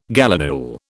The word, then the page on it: gallinule: British English